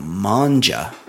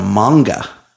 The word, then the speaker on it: manga: I always thought it was called manja.